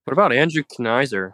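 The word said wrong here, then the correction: Knizner: Is that how you say his name? – Kisner. – Kisner, bro.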